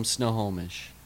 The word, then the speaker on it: Snohomish: Royhan Jenkins from Snohomish. Yeah. Is that how you pronounce it? – Yeah.